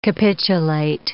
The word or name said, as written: capitulate